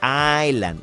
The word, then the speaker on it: island: no se pronuncia esa primera S, Island.